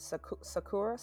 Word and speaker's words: sakura: Uh, I guess in American terms, people say sakura or yeah, or, or, sakura blossoms. Yeah, but in Japanese, it's sakura. Sakura.